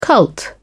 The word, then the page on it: cult: British English